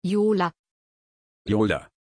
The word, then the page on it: Yola: German